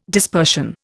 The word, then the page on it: dispersion: Indian